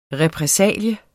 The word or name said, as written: repressalie